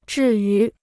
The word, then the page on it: 至于: zhì yú